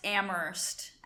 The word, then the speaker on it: Amherst: It's pronounced Amherst, actually. You don't say the H, so.